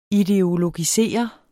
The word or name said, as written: ideologisere